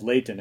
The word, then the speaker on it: Layton: there's a town called Layton, and it's spelled Layton. The kind of the slurred Utah accent way to say it would be to just say Layton and to kind of drop the T.